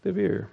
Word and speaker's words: Debir: Then we have Deborah, Debir, Debbie. No, not Debbie, I'm sorry. We have pronunciation of the beer.